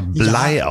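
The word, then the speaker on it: Bligh: So, und wir erinnern uns an die letzte Folge, als ich über den Kapitän der Bounty gesprochen habe, den ich Bleach genannt habe, der wird aber Blei ausgesprochen.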